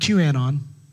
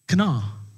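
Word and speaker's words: Kanna: It's pronounced Kana, not QAnon. Okay? Don't get it twisted. Kana.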